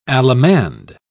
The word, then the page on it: allemande: British English